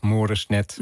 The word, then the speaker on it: Moresnet: Zeg ik het zo goed trouwens? – Ja, nou ja, het is een beetje, het ligt op een soort linguistisch kruispunt. Dus elke uitspraak is goed. Ik zeg zelf Moresnet. De Fransen leggen de nadruk op de laatste klemtoon. De Duitsers op de eerste.